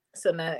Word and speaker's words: Cenat: Kai Sinat? – Sinat, yeah.